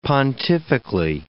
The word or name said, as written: pontifically